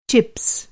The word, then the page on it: चिप्स: Hindi